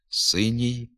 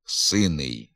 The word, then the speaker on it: синій: У м'якій групі прикметників кінцевий приголосний основи завжди пом'якшується. Наприклад, синій, синя, синє, синього, синім. Тверда вимова приголосного перед закінченням синий, сина, сине, синого, синим характерна для південно-західних говорів, є відхиленням від літературної норми.